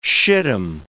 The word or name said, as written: shittim